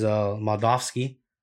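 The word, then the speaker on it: Moldavsky: And then also on Saturday, we have Bellator 273, Bader versus Moldovsky. I think that's how you pronounce it. Am I right? – Yeah.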